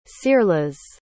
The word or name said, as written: searlas